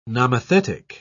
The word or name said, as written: nomothetic